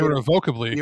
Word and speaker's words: irrevocably: Dungeons & Dragons' new OGL will be irrevocably, I can't pronounce that word, and bring mechanics to create... – Irrevocably. – Irrevocably.